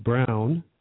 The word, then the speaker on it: Braun: I mean, it's amazing back through our history for instance, in my wife's family, they have Braun, B-R-A-U-N, which many people pronounced Brown.